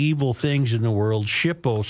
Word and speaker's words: SHPO: The mansion is on the National Register of Historic Places and thus is under the eye of the State Historic Preservation Office, or SHPO, pronounced SHPO in government acronym alphabet soup lingo.